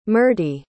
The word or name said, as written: Myrtie